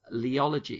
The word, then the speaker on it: Leology: And you explore a lot of these challenges in something you've set up called the Leology Institute. Am I pronouncing that correctly, by the way? – You are. Yeah.